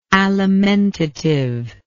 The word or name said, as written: alimentative